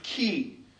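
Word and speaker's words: key (כי): In Hebrew, there is a little word pronounced key.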